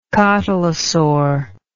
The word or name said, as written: cotylosaur